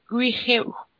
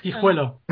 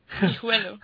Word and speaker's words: Guijuelo: I hope I pronounced it correctly, Guijuelo. – Not even close, but thanks for trying. – I don't know. – Yeah, that's Guijuelo. – Guijuelo, okay. Guijuelo. – Yes.